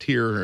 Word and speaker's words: Tyr: So I'm going to play a song from the band Tyr, or Twitter, T-Y-R, is how you spell it.